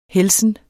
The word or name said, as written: helsen